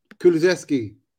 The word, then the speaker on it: Kulusevski: Kulusevski.